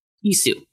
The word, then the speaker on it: Isu: the Iso. Is that how it's pronounced? – Isu.